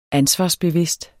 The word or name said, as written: ansvarsbevidst